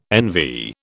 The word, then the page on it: NV: British English